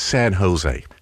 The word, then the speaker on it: San Jose: He said, "No, mate, San Jose." He said, "Sir, in the United States, we sort of pronounce our J's like an H, so it's San Jose."